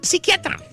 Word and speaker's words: psiquiatra: Sí, pero con el doctor psiquiatra. – Doctor psiquiatra. – La eh. P no se pronuncia, muchacho.